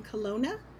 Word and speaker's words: Kelowna: Kelowna.